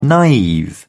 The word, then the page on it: naive: British English